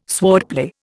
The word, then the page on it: swordplay: Indian